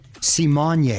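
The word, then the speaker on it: Raven-Symoné: This time it's Raven Simone. She says it's actually pronounced Simone.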